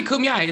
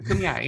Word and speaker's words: Kumeyaay: It's okay, Kumeyaay. It's all right. It's, no one gets it right the first time. Kumeyaay, yeah.